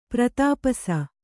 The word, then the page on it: ಪ್ರತಾಪಸ: kannada